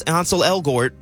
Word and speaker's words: Ansel Elgort: He said, quote, if you can pronounce Ansel Elgort, and I don't even know if I'm pronouncing it right. – You did.